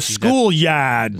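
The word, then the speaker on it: Schoolyahd: There's a place called The Schoolyard, and I say it that way because it's spelled Y-A-H-D. The Schoolyard.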